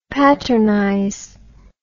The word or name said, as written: patternize